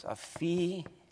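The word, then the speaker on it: aphiemi (ἀφίημι): Pronounce it like this, aphiemi.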